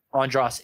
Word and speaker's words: Andras: Yeah, now I said it right. Andras Jones. That's how you say it.